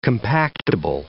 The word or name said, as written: compactible